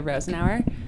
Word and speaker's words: Rosenoer: My name is Mika Rosenauer, M-I-C-H-A, and my last name is R-O-S-E-N-O-E-R.